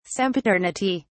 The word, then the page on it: sempiternity: British English